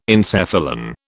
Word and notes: encephalon: British English

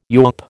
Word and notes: yawp: American English

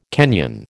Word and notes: Kenyan: American English

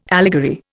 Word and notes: allegory: Indian

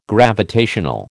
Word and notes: gravitational: American English

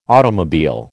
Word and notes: automobile: American English

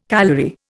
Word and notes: calory: Indian

